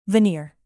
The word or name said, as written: veneer